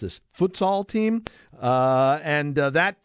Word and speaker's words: futsal: Otto, how did I say it anywhere close to correct? Good morning. Yes, you did very well with that. – Futsal. Okay, because I have no idea what it is until I started reading your bio, but that's, I don't want to skip ahead.